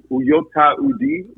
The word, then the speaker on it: Uyoata Udi: Yes, my name is Uyoata Udi,